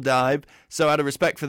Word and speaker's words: DIIV: It is, in fact, I believe, D-I-I-V, but it was Dive, because they originally called Dive